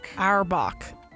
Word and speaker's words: Auerbach: Auerbach.